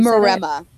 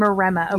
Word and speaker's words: Maremma: You'll hear them called Maremmas, but I was told by someone who speaks Italian that's Maremma.